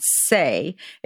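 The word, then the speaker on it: Saie: But Lainey Crowell is the founder and CEO of a company called Say.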